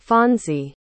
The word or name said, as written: fonzie